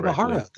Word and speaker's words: Imahara: Imahara, that's right.